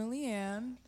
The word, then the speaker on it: Leanne: My name is Mona Leanne. – Oh, that's how you say it. – Yeah. – I did Mona not want to say it wrong. No, I was like, Lion, Lie, Lie.